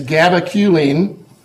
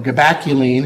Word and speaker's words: gabaculine: Its name is gabaculine, or gabaculine, if you prefer that pronunciation.